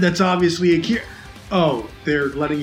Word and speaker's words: Akira: Well, it's because it's correctly pronounced Akira. – Right. – Akira.